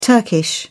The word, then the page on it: turkish: British English